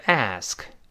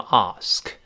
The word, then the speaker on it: ask: 这个 ask 美式发音，英式发音读成 ask，美音中 a 一般变成 a，然后 ask for 跟后面 it 连读。